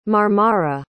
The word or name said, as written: marmara